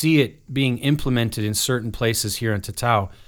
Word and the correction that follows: Tatoi: like, am I saying that right? – Tatoi. It's Tatoi.